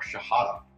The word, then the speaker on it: Shihadeh: I am here today with another friend and a certainly special guest, Mark Shihadeh.